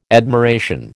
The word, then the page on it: admiration: American English